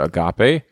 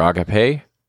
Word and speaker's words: agape: If you've spent time in church, you've probably heard people refer to it as that original Greek word agape, or agape, however you pronounce it, or choose to pronounce it.